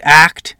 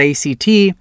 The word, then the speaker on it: ACT: Within acceptance and commitment therapy, for example, pronounced ACT, not ACT,